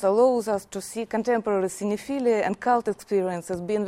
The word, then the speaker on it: cinephilia: Additionally, this problem of strange love for strange cinema is discussed with the application of the concept cinephilia. Am I right in pronouncing cinephilia? Cinephilia. Yeah.